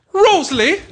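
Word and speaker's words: Rösle: R-O-S-L-E. – Rosley!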